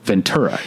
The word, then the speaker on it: Ventura: But once you've lived here, you know Ventura. It's a hard T.